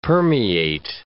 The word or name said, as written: permeate